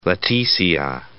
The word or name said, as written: Leticia